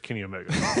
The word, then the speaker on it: Kenny Omega: Kenai Omega. Is that how you say it? – I'm pretty sure it's Kenny Omega.